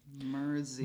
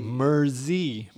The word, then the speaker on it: Mercy: Yeah, and he said we're pronouncing it Mercy, M-E-R-C-Y, like Mercy, Mercy, Mercy by Joe Zo and all. So I wrote back, hey man, thanks a lot. Would you mind phonetically spelling that? And then he wrote back in all caps, M-E-R space Z-E-E. – Mer-Z. – Mer-Z. – With a Z, not a S.